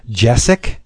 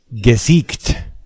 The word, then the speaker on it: Gesicht: I was calling his name Jessic. It's not. Let me try and get this right. – Gesicht.